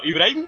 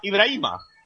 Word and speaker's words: Ibrahim: Eh, por cierto, Ibrahim se llama Ibrahim, no se llama Ibrahima. – Ibrahim. – Pues lo habré soñado yo. – No, se escribe Ibrahima, pero todo el mundo dice Ibrahim.